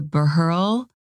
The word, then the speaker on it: Buehrle: And I remember the Burrell collection in Switzerland, which I'm not sure if I'm saying that correctly. – No, I think you did. – It's spelled BUEHRLE, collection in Switzerland.